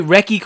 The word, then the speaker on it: Recce: And what am I saying it right? Recce Company, is it? – Yeah, that's right.